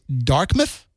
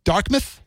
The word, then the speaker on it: Dartmouth: I think back when I was on Mid Mornings, that people actually pronounce Dartmouth as Darkmouth. That people actually say a K instead of a T in the middle. So it's Darkmouth and not Dartmouth.